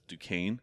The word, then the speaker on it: Duchesne: So it's spelled like Duchesne, Duchesne, but it's Duquesne. It's like a French thing.